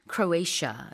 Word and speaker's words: Croatia: 자, 그리고 크로아티아는 영어로는 Croatia 이렇게 발음하고요.